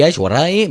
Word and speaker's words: Aishwarya Rai: Abhishek Bachchan y la ex Miss Mundo Aishwarya Rai, que ya ha aparecido en varias de estas noticias y pese a ello es que no hay forma de que pronunciemos bien. Abhishek Bachchan y Aishwarya Rai, muy bien, así más auténtico, pues tenían un romance en secreto.